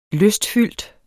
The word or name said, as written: lystfyldt